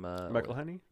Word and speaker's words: McElhenney: Ryan Reynolds and Rob from McElhenney.